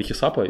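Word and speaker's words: hesap: Ну, точно так же пару раз, два похода в кафешку, в каждом по три хисапа, и все. Ну, в смысле, что типа, хисап, да, правильно сказал? Правильно сказал, отлично.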